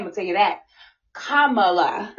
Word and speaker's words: Kamala: said Kamala, and she said it's Kamala, like comma, Kamala.